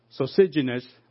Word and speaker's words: Sosigenes: And his name, sorry my pronunciation, sounds like Sosigenes. Let me spell it for you, or you can Google it. S-O-S-I-G-I-N-E-S. S-O-S-I-G-I-N-E-S.